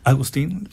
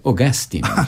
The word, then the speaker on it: Augustine: Agustín, sí. Agustín, fíjate que en Inglaterra se la pronuncia Augustine.